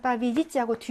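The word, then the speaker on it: 빠비지찌하고 (победить): 이 승리라는 명사는 여러분 빠비에다하고 얘의 강세가 있고요. 승리하다 동사는 빠비지찌하고 뒤에 강세가 있어서 여기는 이 발음이라고 여기는 얘 발음이 나는 겁니다.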